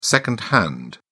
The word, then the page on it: second hand: British English